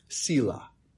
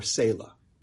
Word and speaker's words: Selah: And then it ends with Selah, or Selah. I think you can pronounce it both ways, which many believe to be some kind of a musical term, a musical mark intended to imply a pause.